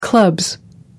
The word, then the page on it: clubs: British English